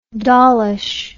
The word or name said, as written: dollishness